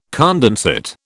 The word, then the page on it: condensate: British English